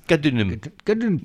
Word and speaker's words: Kadınım: Enfin, le morceau s'appelle Hoş Geldin Kadınım. – Kadınım, pardon. Kadınım. – C'est pas I, c'est U.